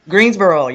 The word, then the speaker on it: Greensboro: You guys are from Greenbow, North Carolina, right? – Greensboro, yeah. – Greensboro, excuse me. Said that wrong.